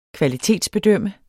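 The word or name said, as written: kvalitetsbedømme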